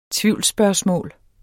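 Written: TVIVLSSPØRGSMÅLET

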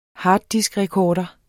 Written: harddiskrecorder

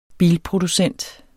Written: bilproducent

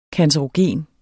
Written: cancerogen

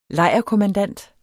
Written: LEJRKOMMANDANT

